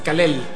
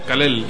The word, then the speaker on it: Kal-El: Pero mira, ¿es una sola palabra? ¿O no, así no, como la pronunciaste? Es Kal-El. – Kal-El.